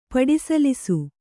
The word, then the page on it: ಪಡಿಸಲಿಸು: paḍi salisu